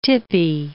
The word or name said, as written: tippy